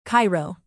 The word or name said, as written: cairo